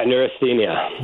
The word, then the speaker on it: neurasthenia: A word that you use a lot in your book, and correct me if I pronounce it incorrectly, uh, neurasthenia. – Yeah, neurasthenia.